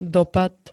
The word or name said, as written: dopad